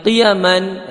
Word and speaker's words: qiyam: Fi dinihil qiyami, nah, qiyam di sini bi takhfifil ya, ya-nya tidak bertasdih. Bukan qayyim, namun bacanya qiyam. Maka syair ini atau bait ini bacanya adalah qiyam, bukan qayyim.